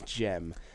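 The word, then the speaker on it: Cem: But I believe it's Jem. But even though it's spelled Sem. – Okay. – I believe it's Jem.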